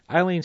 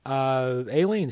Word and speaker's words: Aileen: Eileen Stanley and Billy Murray, Aileen Stanley, as it is more properly pronounced.